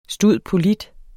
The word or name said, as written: stud.polit.